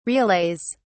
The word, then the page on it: relays: British English